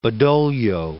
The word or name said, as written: Badoglio